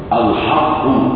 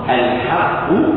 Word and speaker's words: الحق: الحق من ربي هذا خطأ. الحق من ربي هذا صواب.